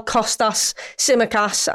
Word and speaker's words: Kostas Tsimikas: Well, Kostas Tsimikas, I'll say his name correctly for you all, played 90 minutes for Greece.